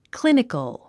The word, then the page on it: clinical: klínikəl; クリニカル